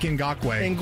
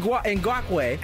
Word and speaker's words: Ngakoue: Unique Ngakoue. – Ngakoue.